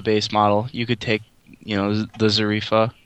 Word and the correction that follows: Zafira: Is that how you say it? – Zafira. – Zarifa. I'm sorry.